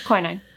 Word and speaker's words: quinine: No, she gave him quinine. Am I saying that right? Mm-hmm. I'm always suspicious now when I'm saying words I'm not familiar with. – Yeah, quinine.